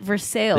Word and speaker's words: Versailles: It's in Versailles, Kentucky, which is kind of like France, except for they pronounce it Versailles.